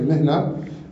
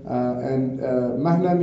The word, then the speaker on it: مهنة: So this word مهنة is usually in spoken Arabic pronounced as mihna. Al-Asma'i, who was one of the great linguists of the Arabic language, he says that it's the word is mahna, it's not mihna.